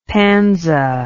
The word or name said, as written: panza